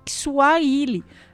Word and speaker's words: Swahili: Na verdade, a pronúncia é Swahili, né? Eles falam diferente da gente.